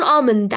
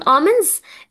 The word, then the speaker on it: almonds: عين الجمل خلاص اسمها والنتس، والنتس في امن الاي لا تنطق ولا دي على فكره، يعني ممكن تقول امند على فكره عادي، بس قصدي لو انت بتقول امنز الدي لا تنطق، بتاخدها بسرعه قوي امنز ماشي، بس انت لو بتقولها الوحيده تبقى امند، يعني ال الوحيده يا جماعه اللي بتتنطقش فعلا قطعا، ال لا تنطق.